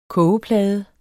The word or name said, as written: kogeplade